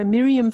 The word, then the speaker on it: Miriam: It's my name, so Miriam Verbeek, but turned around.